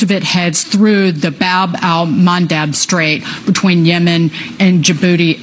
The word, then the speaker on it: Bab al-Mandab: Bab al-Mandab Strait. – You did pronounce it right.